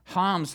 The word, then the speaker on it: Ham: Now, what we see in verses 6 to 20 is Ham's line. And so the best way to, in the Hebrew, you have to act like you're clearing your throat when you say his name. Ham.